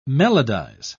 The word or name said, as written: melodize